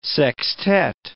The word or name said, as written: sextet